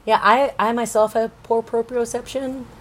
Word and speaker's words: proprioception: Proprioceptal? – Yes. – Proprioceptal? – Proprioception? – Proprioception.